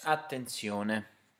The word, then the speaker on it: attenzione: So, finally, words which end in "-tion", in English, end in "-zione", in Italian. Can you guess how to say attention in Italian? How do you say it? – Attenzione.